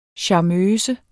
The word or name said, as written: charmeuse